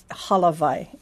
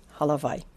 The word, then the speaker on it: Halavai: It's Halavai, H-A-L-A, Halavai.